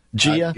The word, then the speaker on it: Jia: Is that how you pronounce J-I-A? – Yeah. – Gia? – Yes.